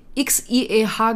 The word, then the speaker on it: Xie: Das war XI, XIEH geschrieben, es wurde Xie ausgesprochen.